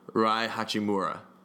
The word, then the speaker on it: Rui Hachimura: Here, let me not butcher this. Rui Hachimura.